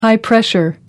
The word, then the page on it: high pressure: American English